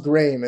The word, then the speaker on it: Graeme: So it's pronounced Graeme. It's like frame with a G.